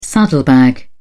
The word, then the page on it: saddlebag: British English